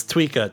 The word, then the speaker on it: Tweeka: Is it Tweeka or Tweika? Am I saying it wrong? – It's Tweeka. T-W-E-E-K-A.